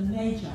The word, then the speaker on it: magi: So what we're going to talk about is the wise move, the magi. I actually saw, I've been pronouncing it wrong all these years. You learn something every day. It's M-A-G-I, magi.